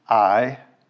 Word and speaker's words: Ai: Sometimes that's pronounced Ai. I think the correct pronunciation, according to my Hebrew professor, is Ai, which is near Beit Avon, east of Bethel, and said to them, "Go up and spy out the land."